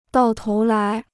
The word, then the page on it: 到头来: dào tóu lái